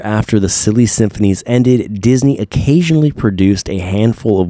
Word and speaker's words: Disney: Years later, after the silly symphonies ended, Disney, that's how you say it in Portuguese, Walt Disney.